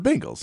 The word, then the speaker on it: Bengals: It's pronounced Bengals or Bengals. It's the Bengals.